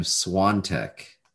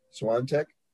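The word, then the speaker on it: Świątek: Oh, so I think they pronounce her name Swiatek. – Swiatek. – Yeah, there's like somehow an N comes out of that pronunciation.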